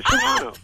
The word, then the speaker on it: Shimano: Another name I'm going to probably butcher, Shimano Torium 30 conventional fishing reel. You tell me how to pronounce that $137 fishing reel, please. – Oh, you got it right. That's good. Shimano.